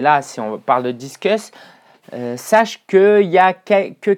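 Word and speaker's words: Disqus: Et puis comme ça je vais pouvoir le présenter à donc ce plugin s'appelle Discus, ça s'écrit D-I-S-Q-U-S. D-I-S-Q-U-S.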